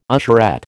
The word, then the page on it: usherette: American English